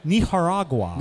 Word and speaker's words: Nicaragua: in which they then made sure they pronounced it ethnically correct. – Nicaragua. – So they were like, ah, yes, when I was in Nicaragua. – Nicaragua. – So whenever that word comes up in conversation, I have to stop, pronounce it that way for my wife. – There you go. – My wife, if she's not around, I say Nicaragua like a New Jerseyan. But if she's around, I say it like... this fella.